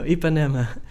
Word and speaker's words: Ipanema: Now, you're in Empanina, right? – No, Ipanema. But that's funny. – Oh, how did I say it? – You said it like empanada almost.